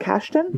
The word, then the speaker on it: Kasdan: It's pronounced Kasdan? – Kasdan. That's how he pronounced it.